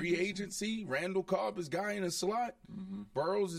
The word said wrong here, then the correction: Berrios: is out. Did I say his name right? The slot, the kid from the, that play in the slot that the Jets lost? – You talking about Braxton Berrios, yeah. – Braxton Berrios, he's gone.